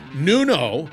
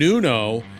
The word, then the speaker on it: Nuno: All right, once again, that's Nuno, because I mispronounced it in the beginning. Nuno and Pedro, Emitu is the company, a must.